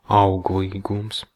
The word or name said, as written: productivity